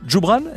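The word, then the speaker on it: Joubran: Trio Djoubran, est-ce que je le dis bien ? – Exact, c'est parfait.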